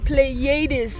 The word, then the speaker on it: Pleiades: Pleiades. – It is Pleiades.